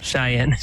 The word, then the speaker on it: Cheyenne: God, my goodness, I cannot pronounce Cheyenne. – These words. – Cheyenne.